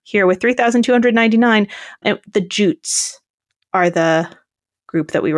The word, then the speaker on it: Jutes: Wikipedia has it with the IPA, International Phonetic Alphabet, like pronunciation guide, has it with a J as in jam. – Okay. – Jutes.